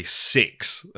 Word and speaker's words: CeX: We went to a sex store, which is spelled CEX, and they sell like secondhand electronics.